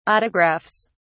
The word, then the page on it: autographs: British English